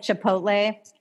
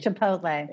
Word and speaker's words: chipotle: And I learned recently it's not chipotle, it's chipotle. – Chipotle. – And now I'm so embarrassed. – At least you didn't say chipotle.